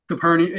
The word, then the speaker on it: Capernaum: Capernaum? – Capernaum, either one's correct.